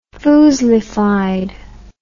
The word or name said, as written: foozlified